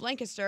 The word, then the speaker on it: Lancaster: I'm so proud of Carla Marie for correcting Anthony of his pronunciation of Lancaster on the last podcast. – How do I say it? – Lancaster. – Lancaster. – Yeah.